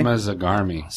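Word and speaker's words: Cyma Zarghami: Except for the fact that Nickelodeon president, her name is hard to pronounce. It's Syma, C-Y-M-A, Zargami, Z-A-R-G-H-A-M-I. – Syma Zargami. – It's like a magic word.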